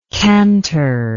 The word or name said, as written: kantor